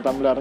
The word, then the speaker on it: Tumblr: Tapi sama-sama tumbler ya? – Ya dibacanya sama-sama tumbler.